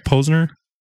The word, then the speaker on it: Posner: Am I saying that right? Posner? – Posner. – Posner. – Gerald Posner. Posner. Yeah.